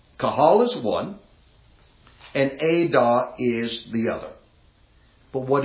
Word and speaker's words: edah: And it is the word Ada. And you would spell it in English, E-D-A-H.